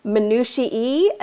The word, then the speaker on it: minutiae: Have you ever heard it pronounced minutiae? And I was like, there's no possible way that's a real word. There's no way. It turns out that is how it's pronounced.